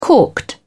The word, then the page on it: corked: British English